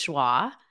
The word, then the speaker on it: Joie: Yes, you were the first to catch that people were saying my name incorrectly because the proper pronunciation is Joie. But my whole life, my mother also called me Joey. So it's fine. People either call me Joie or Joey. I answer to both.